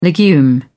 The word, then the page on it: legume: British English